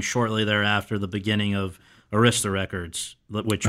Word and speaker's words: Arista: We all, in New York, we all know how to pronounce it. – Yes. – Because it's a name of an honor society that every high school has. – Right. – It's called Arista.